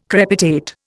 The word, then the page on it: crepitate: Indian